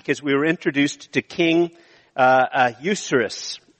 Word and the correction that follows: Ahasuerus: which I didn't pronounce his name correctly. I have a hard time pronouncing his name. Uh, but it's about King Ahasuerus, uh, who's the emperor of Persia.